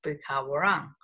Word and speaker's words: Bukawarang: And that became known as the middle lip, which in the local language is pronounced Bukawarang.